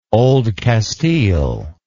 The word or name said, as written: Old Castile